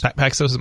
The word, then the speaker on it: Paxos: Paxos, am I saying that right? – Yeah, they're one of them too.